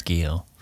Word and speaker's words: Guyot: I think it's Guyot. – Yeah, so we'll go with that. – So I went to high school with someone with the last name Guyot, and it was spelled that way. So, but I'll go with the French pronunciation of Guyot for our purposes.